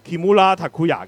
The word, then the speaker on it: 木村拓哉: 嗱，木村拓哉個日文咧，其實就係叫 Kimura Takuya 嘅。